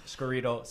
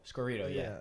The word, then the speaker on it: Scorito: Scorrito. Sign up. – Scorrito. – Scorrito, Scorrito. How do you spell it? S-C-O-R-I-T-O? – It's like Dorito. – Like Dorito Scorrito.